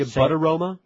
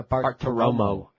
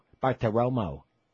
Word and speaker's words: Bartiromo: Maria Bartiromo? – Say it right. Maria Bartiromo. Bartiromo.